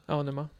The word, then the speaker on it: Aonuma: It's because, I don't want to butcher his name, but our producer, Aonuma? – Aonuma, yeah.